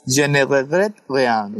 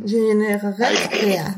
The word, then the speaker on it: Je ne regrette rien: Je ne regrette rien. – Je ne regrette rien.